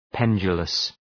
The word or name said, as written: pendulous